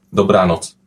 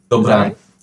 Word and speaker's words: dobranoc: Good night is dobranoc. – All right. Well, dobranoc? – Dobranoc. – Dobranoc? – Exactly.